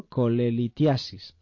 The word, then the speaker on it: colelitiasis: coletiliasis. Lo he dicho bien, ¿no? Colelitiasis, así sí.